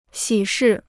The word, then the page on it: 喜事: xǐ shì